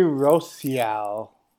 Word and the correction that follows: Rousseau: defensive end. – Rousseau.